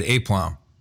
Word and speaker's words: aplomb: And he goes, how do you pronounce this word? And he pointed to the word aplomb. Mm-hmm. And I said, aplomb. And he goes, seriously? And I was like, yeah. And he goes, it's aplomb.